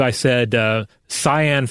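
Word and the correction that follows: Siân: Phillips. It is Sean Phillips.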